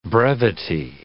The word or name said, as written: brevity